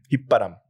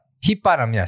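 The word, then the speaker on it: 휘파람: How do you say it in Korean? – 힙바람. – 힙바람, yes. Okay.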